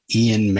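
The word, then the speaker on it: Eoin: Uh, the art is by Ian Marron. I think that's how you say the name. It's E O I N.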